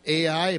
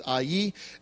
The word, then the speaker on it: Ai: It's pronounced Ai. Some people say Ai, but the correct pronunciation is Ai.